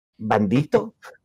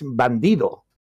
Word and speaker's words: bandido: Bandito? You don't know Spanish. In Spanish it's bandido.